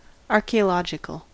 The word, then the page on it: archaeological: American English